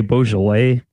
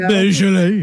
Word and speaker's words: Beaujolais: Yeah, is that how it's pronounced? I heard Hillary say Beaujolais. – Oh, Beaujolais.